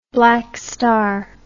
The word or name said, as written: black star